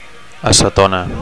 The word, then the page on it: acetona: Catalan